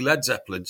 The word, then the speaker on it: Led Zeppelin: But they spelt it L-E-D because they said the Americans will pronounce it as lead and it's got to be Led Zeppelin.